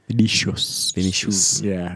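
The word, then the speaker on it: Vinícius: Vinicius? I always got to say his name. – Vinicius.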